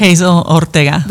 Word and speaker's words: Hazel Ortega: Hazel Oretga.